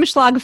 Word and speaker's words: Schlag: Yeah, so at Kim Schlag Fitness everywhere. So my last name is spelled S-C-H-L-A-G.